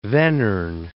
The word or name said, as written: Vanern